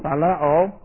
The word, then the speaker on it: phileo: P-H-I-L-E-O, long O, phileo. And phileo is the verbal, and the cognate noun is philos. P-H-I-L-O-S.